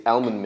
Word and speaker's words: almond: It's spelled A-L-M. – It's almond. – Almond. You got to say the L before the M. – Oh, gosh. – Almond milk. – So, dear listeners, how would you pronounce the word? – Almond. – Almond. – It's almond. It's not... Almond. – Oh, sorry. – You're not even saying the L in almond. – It's a silent L, honey.